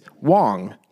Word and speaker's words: Wong: The small blind, who's going to be our protagonist along with Chong, who was the initial opener with the sixes, is Wong. – Yeah. – Unfortunately, rhyming with Chong.